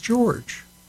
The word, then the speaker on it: Gyorgy: Here is a woman whose first name is Katie, but it is spelled C-A-I-T-Y. That's Katie. And her second name is G-Y-O-R-G-Y, but it's pronounced George.